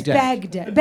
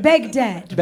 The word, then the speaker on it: Baghdad: No, it's Baghdad. Baghdad. – Baghdad. – Then, is how the proper pronunciation.